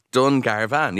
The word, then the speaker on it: Dungarvan: Other way can you pronounce that? – Dungarvan, yes. – Oh, really? – No. You're saying it right.